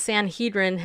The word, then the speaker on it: Sanhedrin: The nascent Sanhedrin has addressed a letter to the two candidates emphasizing the role of the third temple in municipal current policy. Did I say it right? Anyway, yes, you're fine.